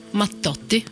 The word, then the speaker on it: Mattotti: Y eso, ¿cómo se pronunciaría correctamente en italiano? – Mattotti. – Claro, hay como una leve pausa entre. Cuando hay dos consonantes, hay como una breve pausa entre una y otra, ¿no? – Es como un poco más fuerte.